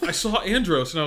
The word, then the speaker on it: Andros: Andres. That's an Andre. How do you say it? – Andros with an O. It's kind of like Davos, like Game of Thronesian.